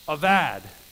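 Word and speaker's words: avad (עבד): When you take that English word to work and you open up the curtain and look behind it, it's this word, avad. You can spell it A B A D, bad, but it's avad.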